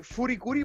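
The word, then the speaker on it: FLCL: Esta, bueno, esta serie tiene como hartas pronunciaciones, depende de dónde sea, pero yo le voy a decir Furikuri porque así se dice en Japón y prefiero decirle así que como le dicen los gringos.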